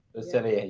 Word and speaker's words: Le Cellier: How do you say it? Le Cellier? – Le Cellier, yeah. – Yes. Nailed it.